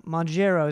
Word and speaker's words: Mounjaro: Mounjaro is how we pronounce it.